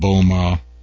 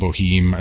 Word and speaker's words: Boehme: Jacob Boehme, Jacob Boheme, as it might read for those not trying to pronounce it the German way, and other contemplative mystics.